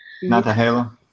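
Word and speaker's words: Nantahala: Like is it Natales, or how do you pronounce that? – Natahala.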